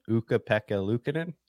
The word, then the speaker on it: Ukko-Pekka Luukkonen: I mean, also, like, I can never say his name right, but Ukko-Pekka Luukkonen. Did I get it? – You got it. You nailed it.